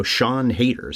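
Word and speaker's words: Siân Heder: And the adapted screenplay by, and I just heard this last night, saw her on a YouTube video, Sean Hader, is how she pronounces her name.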